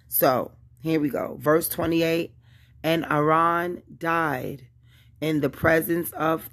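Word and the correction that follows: Haran: And I'm saying that name wrong. And Arhan is Arhan, not Aran.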